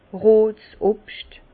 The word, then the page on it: rot Obscht: Bas Rhin; Herrlisheim